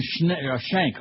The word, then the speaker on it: Schenck: Reverend Rob Schneck, who identified himself as an evangelical Christian and president of the National Clergy Council in Washington. Reverend Schenck, oh, I keep saying Schneck, rhymes with direct.